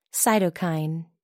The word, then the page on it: cytokine: American English